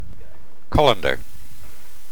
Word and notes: colander: British English

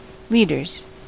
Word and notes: leaders: American English

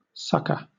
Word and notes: succour: British English

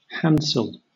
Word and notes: handsel: British English

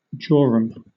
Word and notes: jorum: British English

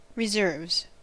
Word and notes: reserves: American English

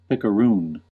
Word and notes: picaroon: American English

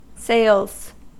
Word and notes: sales: American English

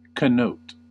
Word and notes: connote: American English

